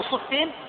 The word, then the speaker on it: صفين: صفين مكان على شاطئ الفرات في آخر حدود العراق وعلى أول حدود الشام، مكان موقع يقال له صفين، وهذا هو النطق الصحيح كما يعني بحثت ونقبت بكسر الصاد مع فاء مشددة مكسورة.